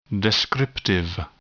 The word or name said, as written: descriptive